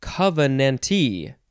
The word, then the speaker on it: covenantee: Now, I think you would pronounce it covenantee. That's one way, covenantee, or just covenant covenantee. I think the T is always emphasized